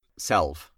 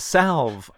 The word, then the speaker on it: salve: And for me, it was a salve. Is that how you pronounce it? It was a balm. – Salve. – It was a slav. It was a salve on the, you know, the sort of like chronic repetitive heartbreaks and crushes that I had throughout those ages.